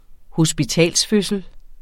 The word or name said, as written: hospitalsfødsel